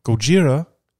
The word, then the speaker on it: Gojira: So it's definitely Gojira, and their new single is Our Time Is Now.